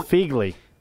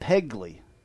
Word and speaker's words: Phegley: Yes, people don't understand that P and H make an F sound. I get Pegley all the time. it's spelled P H E G L E Y.